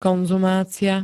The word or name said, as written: konzumácia